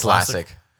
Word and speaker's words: Lasek: It's Lasek. LASIK is the surgery.